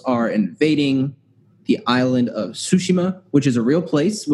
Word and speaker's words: Tsushima: Yes, I was pronouncing it wrong. Tsushima.